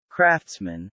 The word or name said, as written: craftsman